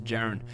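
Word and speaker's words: Jerian: I was calling him Jerry, and apparently it's Jaren.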